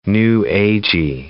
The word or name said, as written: New Agey